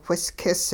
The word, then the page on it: whiskis: hupa